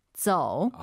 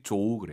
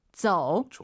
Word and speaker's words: 走: 走. – 아, 이거 정말 많은 분들이 조우 그래요. 조우, 조우, 조우. 오유는 어예요. 저우라 생각하시고. – 走.